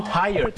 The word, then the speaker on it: tired: เหนื่อยนะครับอย่าลืมครับออกเสียง tired สองพยางค์นะครับ T-I-R-E-D ไม่ใช่ try รอเรือแล้วก็ไม่ใช่ไทยพยางค์เดียวนะฮะ